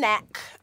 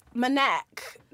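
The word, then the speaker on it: MNEK: Oh, is it neck. Manek. It's M N E K.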